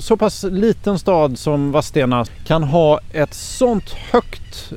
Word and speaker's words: Vadstena: Vilken dag vi har haft i Vadstena, för det är så man uttalar stadens namn.